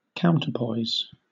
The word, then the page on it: counterpoise: British English